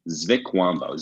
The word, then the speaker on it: Zvikwambo: Yeah, Zvikwambo. Z-V-I-K-W-A-M-B-O. Zvikwambo.